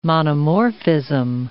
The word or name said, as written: monomorphism